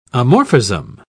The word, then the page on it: amorphism: British English